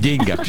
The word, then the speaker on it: деньгах: В общем, друзья мои, ударение на букву «Е» в деньгах считается устаревшей формой. Потому что употребление допустимо лишь в случае пословиц, поговорках, как, например, «не в деньгах счастье». Действительно, здесь можно сказать «не в деньгах». Во всех остальных случаях на второй слог. Деньгах.